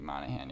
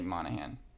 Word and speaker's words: Monaghan: Is that how you pronounce his name? – It's like Dominic Monaghan, yeah. – How do you say it for the last name? – Dominic Monaghan. – Good. Yes, him.